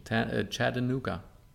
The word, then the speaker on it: Chattanooga: Und das ist eine Stadt in Tennessee, Chattanooga.